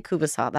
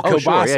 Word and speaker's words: kielbasa: In Alberta, we say kubasa. That's what the sausage is called. – Okay. – And you guys call it kielbasa? – Oh, kielbasa. Sure.